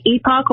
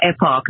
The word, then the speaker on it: Epoch: You can say Epoch or Epoch. They'll say both are fine, so it's really fine.